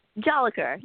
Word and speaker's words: Jolicoeur: Did I say it right? Jolicoeur? – Jolicoeur, yeah.